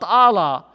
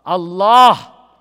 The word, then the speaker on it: Allah: not Allah, Allah, accent on the second syllable.